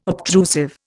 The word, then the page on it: obtrusive: Indian